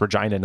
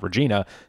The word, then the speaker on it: Regina: Sort of another country song, not explicitly about Canada, but they do name Regina, yes, it's pronounced Regina, not Regina, several times in the chorus even.